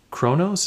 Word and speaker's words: Kronos: Kronos. Is that how you pronounce it? – Uh-huh. Yep, you got it.